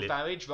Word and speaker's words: Farage: Nigel Farage rhymes with garage.